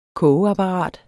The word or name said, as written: KOGEAPPARATET